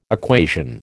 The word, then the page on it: equation: American English